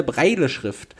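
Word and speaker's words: Braille: der dänische Spielwarenhersteller Lego und seine Lego-Stiftung verschenken Steine mit der Blindenschrift, äh, jetzt hilf mir mal kurz, wird es Breil ausgesprochen? Breile-Schrift. Breile-Schrift, ja, okay, also wirklich so, wie es geschrieben wird.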